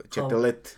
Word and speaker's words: Châtelet: muchas veces nada más la ponen como Madame de Chetulit. Ok. – Chatelet.